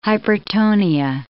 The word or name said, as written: hypertonia